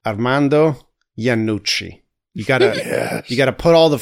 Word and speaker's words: Iannucci: Today we're here to talk about The Death of Stalin from director Armando Iannucci.